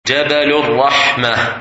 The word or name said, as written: Mount of Mercy